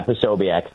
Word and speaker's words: Posobiec: Yeah, Posobiec.